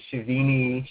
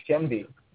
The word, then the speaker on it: Shenvi: No, that's right, Dr. Shenvi. I've heard all other kinds of variations, Shavini, Shevni. It's just phonetic, Shenvi.